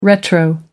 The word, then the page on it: retro: American English